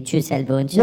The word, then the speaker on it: Gisele Bündchen: Let me just say, hear it the way you normally would hear it. Pronounced in the United States would be Gisele Bündchen.